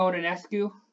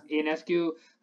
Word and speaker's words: Ionescu: So, I mean, Sabrina Ionescu, I believe is how you say it. – Ionescu, who she just was kind of the rising star of women's basketball this year.